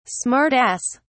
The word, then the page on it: smart ass: British English